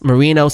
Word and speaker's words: Marino: And it's Marino spelled the same way that Dan spells it.